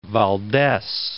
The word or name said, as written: Aleman Valdes